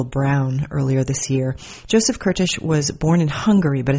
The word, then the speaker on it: Kertész: Today on Write the Book, you're going to hear a conversation with the author Joseph Kertész, or Curtis, depending on how you pronounce it. He's Hungarian. They say Kertész there, but I believe in Canada he goes by Curtis.